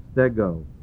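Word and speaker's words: Stego (στέγω): The Greek word S-T-E-G, long O. Stego.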